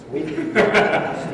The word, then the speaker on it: Wiebe: But the thing is that when you get cut down to the reality of Steve Wiebe, I said it right that time, it's Wiebe.